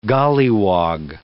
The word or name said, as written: gollywog